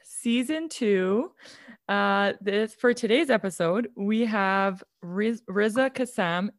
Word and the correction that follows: Razia: Am I saying that right? – No, it's Razia. – Oh, Razia.